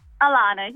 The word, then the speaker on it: Alana: I get every letter of the alphabet put at the end of it, but it's just A-L-A-N-A. – Alana. – Very easy. – Did I say it right? Alana, Alana. – Yeah, Alana, yes.